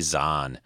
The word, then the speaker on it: Zahn: Tim, Timothy Zahn, Z A H N, but uh, like kind of like Thrawn in a way, but remi- my, rhymes with that.